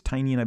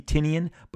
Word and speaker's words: Tynion: I believe it's Tynion. I've been told that's how his name is pronounced. It could be Tynion, but here's the great thing.